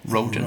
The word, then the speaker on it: Rogen: Eller Rogan, hur uttalar han Rogan. Rogan.